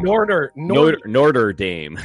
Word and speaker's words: Notre Dame: How do I pronounce it wrong? – Notre Dame.